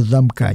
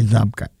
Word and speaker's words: замка: Именно замка, не замка. Ударение стоит ставить правильно.